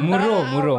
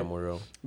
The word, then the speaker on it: Mvro: Wait, what's the V? – It's his style. – You or like you just you like mispronounce it. – Mvro. – Okay, it's fine. – Muro. – Yeah, Muro.